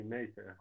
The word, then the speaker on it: Meister: Eu, uh, pronunciei o nome corretamente, Tiago? É, Pronunciou assim, Meister. Uhum. – É, Tiago Meister Carneiro.